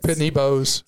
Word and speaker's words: Pitney Bowes: We currently have a Pitney Bowes, is that how you say it? – Pitney Bowes.